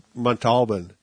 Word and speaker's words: Montalban: Yeah, Ricardo Montalban.